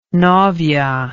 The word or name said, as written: novia